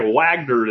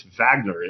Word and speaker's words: Wagner: It looks like Wagner to me, but it's Wagner is how it seems to be pronounced in Russian.